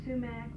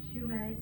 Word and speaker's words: sumac: People pronounce it a lot of different ways. Sumac or shumac or. – I always said sumac. – Okay. Have you ever heard it pronounced the other way? – No.